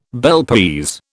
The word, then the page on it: Bel Paese: British English